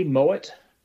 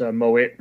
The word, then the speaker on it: Mowat: Yes, Andy Mowat. It's pronounced Mowat rather than Mowat.